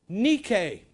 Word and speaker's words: Nike: It is the prefix hooper, which we get hyper from, and the suffix is the Greek word nike, which is the correct pronunciation of Nike.